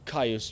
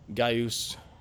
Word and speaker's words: Gaius: Point being, you wouldn't say Caius Julius Caesar. You would say Gaius Julius Caesar.